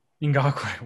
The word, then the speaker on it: Ngakoue: Nkui? – Ngakoue. – Wow. Not even close.